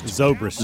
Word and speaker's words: Zobrist: Now, how do you say the guy's name? Ben Zobrist? – Zobrist. – Zobrist? – Yep.